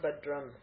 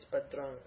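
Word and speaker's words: Bhadrang (भद्रं): That Anuswara that you see there is going to be pronounced like the guttural nasal. Okay, it's not Bhadram, it's Bhadrang, okay.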